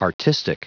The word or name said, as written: artistic